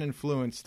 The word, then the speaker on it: influence: I'd like to influence the way you say influence. – Influence? How did I say it? – Influence? – Influence? You're saying influence. – Influence. – I think you're saying it like a retard. – Retard. All right. – You're putting the stress on the wrong syllable.